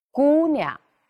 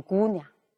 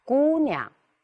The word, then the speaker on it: 姑娘: 姑娘，这个不能读成姑娘，读的太短了。第一个音节呢，适当要加长一点，是吧？姑娘，星星，胳膊，哎，清楚，这样去读，哎，会好一点。